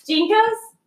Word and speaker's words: JNCO: JNCOs? – Yeah. – Is that how you say it? Uh-huh.